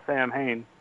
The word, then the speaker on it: Samhain: A word that's spelled S-A-M-H-A-I-N. And somebody says, well, there's some kind of nonsense word on the board here. And then Dr. Loomis says, no, that's Samhain. It's a Celtic word.